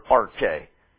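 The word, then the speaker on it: arche (ἀρχή): You get the word arch, that prefix from this word arche. If you transliterate it, it's A-R-C-H, long E.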